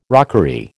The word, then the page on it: rockery: American English